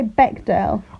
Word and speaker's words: Bechdel: Do you say Bechdel? I say Bechdel. I think you're saying it right.